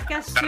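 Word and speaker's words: cassoeula: Ragazzi, sapete pronunciarlo giusto? Io faccio un po' fatica per queste due S vicine che faccio fatica a pronunciare. – Io faccio l'italiano. Non so voi. La cazzuola. Dai. – La cassuola. – Bravo, vedi Ma che sei sul tempo.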